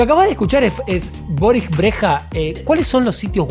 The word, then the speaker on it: Brejcha: Se escribe Boris, así como suena, y Breja se escribe B-R-E-J-C-H-A. – Eh, Rafaela, si me seguís hablando así, te garcho.